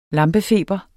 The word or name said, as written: lampefeber